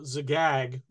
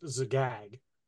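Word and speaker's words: Zagyg: Yeah, that the castle Zagag, which is it's a Z instead of an X, but in American English, a lot of times we pronounce X in a name as Z. Or well, maybe in British English, I don't know. Anyway, spelled backwards, Gygax would be pronounced Zagag.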